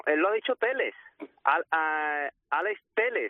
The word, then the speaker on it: Alex Telles: Pero en la presentación, bueno, en el vídeo que ha hecho promocional tras firmar el contrato, él ha dicho Alex Telles, así que pues pronunciaremos Alex Telles,